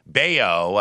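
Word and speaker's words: Bello: Bayo, as it's pronounced, is going to have to try to get out of this jam.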